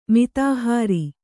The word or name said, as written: ಮಿತಾಹಾರ